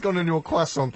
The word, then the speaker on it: croissant: When I was using the correct French pronunciation, croissant.